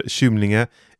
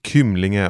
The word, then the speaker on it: Kymlinge: Stationen heter Kymlinge eller Kymlinge om man så vill. Det är lite olika bud om hur man uttalar den här stationen.